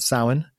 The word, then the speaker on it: Samhain: We've got the celebration at the very end of the month, so 31st, which would be called by many people Samhain, which is spelt Samhain, but Samhain.